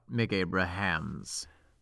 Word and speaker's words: Mick Abrahams: So it was about Mick Abrahams. Emphasis on the ham, am I right? – Oh, he puts the ham in Mick Abrahams.